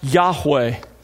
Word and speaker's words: Yahweh: a translation of God's personal name, pronounced Yahweh.